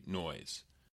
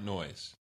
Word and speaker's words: Noyes: And their charismatic leader was a man named John Humphrey Noyes. So, yeah, his last name is spelled N-O-Y-E-S, which can be a little confusing in an audio podcast.